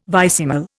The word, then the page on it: vicesimal: Indian